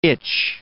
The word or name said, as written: itch